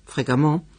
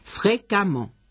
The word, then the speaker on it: fréquemment: Y sobre todo, cuidado con los adverbios terminados en A-N-T, en, E-N-T. Página 305. Abondant, abondamment. Y fréquent, fréquemment, que aunque se escribe fréquemment, que han visto en el libro, se pronuncia como si tuviera una A. Fréquemment, y no fréquemment.